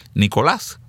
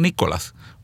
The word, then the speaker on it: Nicolás: Y digo Nicolás porque recuerdo que cuando nació le preguntaba a sus padres que cómo le iban a decir, y mi hijo enfatizó Nicolás con acento en la A, Nicolás, no Nicolás, porque estamos orgullosos de nuestras raíces, de nuestro origen.